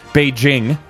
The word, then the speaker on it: Beijing: The Olympics taking place in Beijing. That's the correct pronunciation.